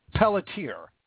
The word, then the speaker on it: Pelletier: I don't mean any offense by it, but I think it's now Pelletier is how you actually pronounce it.